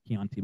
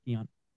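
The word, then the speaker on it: Keaontay: Not Keontae, but Keontae.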